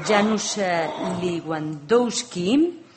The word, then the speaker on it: Janusz Lewandowski: El tercer panelista es polaco, Janusz Lewandowski.